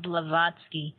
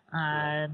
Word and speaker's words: Blavatsky: I'll say her name again for our audience, Helena Blavatsky, Blavatsky, Blavatsky.